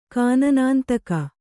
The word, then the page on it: ಕಾನನಾಂತಕ: kānanāntaka